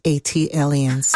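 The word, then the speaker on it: ATLiens: official ambassador for Atlanta, an Atelians. – That is pronounced A-T-Aliens. – Ah, thank you. – Like Atlanta alien, A-T-Alien.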